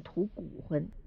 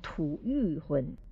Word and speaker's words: 吐谷浑: 那么这个特殊的名字的时候呢，不念吐谷浑，念吐欲浑，就跟我们讲欲望加一个欠字边的那个欲字一样的读音。